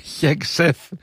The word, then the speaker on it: Χέξεθ: Ναι, και ο Χέξεθ, για να το προφέρω πλέον σωστά, όπως με διορθώνουν, και ο Ρούμπιο, όλοι έχουν τα μούτρα κάτω κάπως.